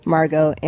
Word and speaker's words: Margaux: So it's C-H-A-R-G-A-U-X. So it looks like Chargox because I spell my name the French way. Margo, M-A-R-G-A-U-X.